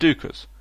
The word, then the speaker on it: Deuchars: Although that has since vanished, and now the best beers from their brewery include the Caledonian 80 and Duchars, which is spelt D-E-U-C-H-A-R-S, and guaranteed to confuse the foreign bar staff in London.